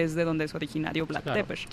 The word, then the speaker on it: Vlad Tepes: Pues estamos hablando de Vlad Tepes, se pronuncia así, me parece, en el rumano, que lo conocemos más bien como Vlad el Empalador.